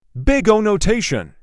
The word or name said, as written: Big O notation